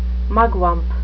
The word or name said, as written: mugwump